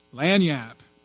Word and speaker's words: lagniappe: Lagniappe. Must be Italian. No. Lagniappe. It's French.